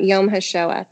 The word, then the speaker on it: Yom HaShoah: Yad Vashem, or no, um, Yom HaShoah.